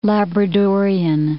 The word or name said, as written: Labradorian